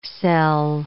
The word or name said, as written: cel